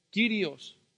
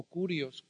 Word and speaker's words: kyrios: En el idioma griego es kyrios o kurios, como lo quieras pronunciar.